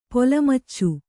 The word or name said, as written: ಪೊಲಮಚ್ಚು